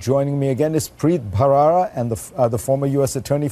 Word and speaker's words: Bharara: And for all my viewers, you're getting the correct pronunciation here, Bharara, aspirated H.